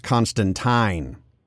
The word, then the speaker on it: Constantine: Also, we are getting the actual pronunciation as Constantine, as originally intended.